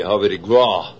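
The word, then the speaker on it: Havre de Grace: As a matter of fact, they pronounced it G-R-A-W, Harbor de Gras.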